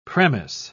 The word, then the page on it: premiss: British English